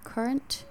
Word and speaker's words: Courant: The first successful English newspaper, the Daily Courant, I think that's how you pronounce that word. – How's that spelled? – C-O-U-R-A-N-T. Current? – Yeah, I would say so.